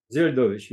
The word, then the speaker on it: Zeldovich: Zeldovich, Kobzarev, Okunin. So they took it very, very seriously. – This is Zeldovich, you say? – Zeldovich, yes.